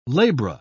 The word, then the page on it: labra: British English